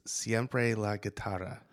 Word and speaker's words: Siempre la Guitarra: The concert series is Siempre la Guitarra. – That's correct. – I got it right? – Yeah, you got it.